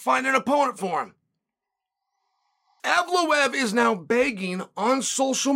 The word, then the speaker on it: Evloev: Mozar Evloev.